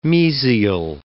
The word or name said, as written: mesial